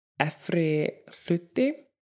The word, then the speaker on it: efri hluti: And actually, outer layer is pronounced efri hluti, Efri hluti, which translates really to like upper part, but in essence, it's outer layer.